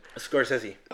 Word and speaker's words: Scorsese: Scorsese, ¿cómo se pronuncia? Scorsese.